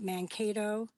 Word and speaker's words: Mankato: And even just learning about things like what happened, and correct me if I'm wrong, the massacre at Mankato. Am I saying it right? – Yep.